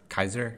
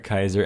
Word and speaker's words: Caesar: So Caesar would have been pronounced Kaiser. – Kaiser and Cicero would have been pronounced Kikero. – Kikero. Okay. – Which is very strange. Kikero does not sound Roman at all, but that's how they would have said it, supposedly.